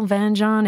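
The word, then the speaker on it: vin jaune: So if Van John is the heart, beating heart of the Jura, I'm going to tell you what the hell Van John is. So it's spelled V-I-N, new word, J-A-U-N, and it translates to yellow wine.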